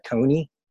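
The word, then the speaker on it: Tone: I don't even know that thing, but my profile is T-O-N-E. I pronounce that Tony. Mm-hmm. F-L-O-R-E-A-L, Flow Real, Tony Flow Real.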